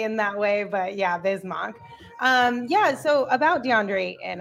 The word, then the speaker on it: Bismack: It always throws you, though, because it's spelled Mack, M-A-C-K. Yeah. So I feel like that's why people always get confused, because when they're looking at the name, they're going to pronounce it kind of like that. But it is Bismack.